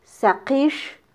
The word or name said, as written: saqix